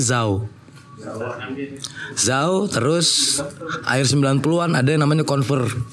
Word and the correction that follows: Converge: Converge gitu bacanya, Converge.